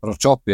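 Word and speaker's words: Racioppi: Also da haben sie den, den, den IB-Goalie Rajopi, hat ja einmal richtig, Rajopi spricht man aus, oder? – Rajopi, ja.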